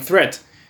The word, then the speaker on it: threat: Watch out for meat and great and threat. They rhyme with sweet and straight and death.